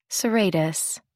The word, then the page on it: serratus: American English